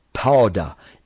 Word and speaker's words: padah: The first one we want to deal with is the word P-A-D-A-H, Pada. It's pronounced Pada.